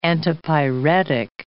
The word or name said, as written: antipyretic